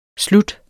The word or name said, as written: slut